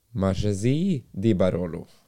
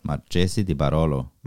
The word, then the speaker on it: Marchesi di Barolo: Antikk kantine di Marge... – Få se på den. – Marchesi di Barolo. – Marchesi di Barolo.